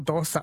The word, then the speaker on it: dosha: I was making sure I hadn't misled you the other day by saying dosha is pronounced dosha, not dosa. I asked my parents, and they said, yes, we say dosha, but some people down south in Kerala say dosa. And apparently, if you're Tamil-influenced in Kerala, you say the sa, and everyone else says sha. So we were both right on the pronunciation.